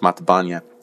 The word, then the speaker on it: Mathabane: The book is written by Mark Matabanya. It looks like the word Mathabane, but it's pronounced Matabanya.